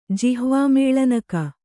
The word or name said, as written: ಜಿಹ್ವಾಮೇಳನಕ